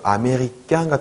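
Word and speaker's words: américain: 그리고 아메리깡 같은 경우는 왜 아메리깡, 깡, 약간 깡이 됐는지는 이따가 세 발음 보실 때 또 보실 텐데요. 일단 중요한 거는 깨처럼, 깨. 까가 아니고 깨잖아요, 깨. 그러니까 깡 되는 거예요.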